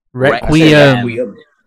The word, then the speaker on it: Requiem: How do you say it wrong five times? Wreck, say it with me. – Wreck. – We. – M. Requiem. – It's Requiem. Not M.